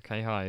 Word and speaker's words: K-High: Or K-Hi is the proper pronunciation.